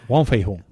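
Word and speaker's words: Wong Fei-hung: Sí, Wong Fei-hun.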